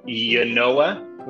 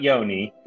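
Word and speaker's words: Ynoa: Our friend Yanoa, which is how you say his name, not Yoni.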